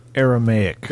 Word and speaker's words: Aramaic: How do you say it? Say the word. You know what I'm talking about. – Aramaic. – There we go.